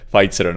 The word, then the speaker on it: Pfizer: s původní německou výslovností, což hodně lidí jo. dělá, že jo? Protože když vidí prostě něco, co je zjevně německý, jako, tak to čte s německou výslovností ale jelikož je to americká firma, tak se to čte Pfizer, no ano, je to, je to tak.